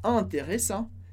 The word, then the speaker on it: intéressant: Importante na pronúncia de intéressant que a gente não pronuncie o T. Então, intéressant, terminando no som de an, sem pronunciar o T. A gente corta o T.